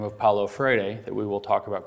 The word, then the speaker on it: Paulo Freire: since I said it, I think, correctly with the Portuguese. I'll spell it for you because he's going to come up a whole bunch of times. It is F-R-E-I-R-E. Paulo is spelled P-A-U-L-O. Paulo Freire.